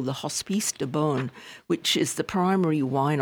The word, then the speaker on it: Beaune: Now, Beaune is, I guess in France, sometimes it's pronounced Bon, It's a medieval town with walled ramparts, etc.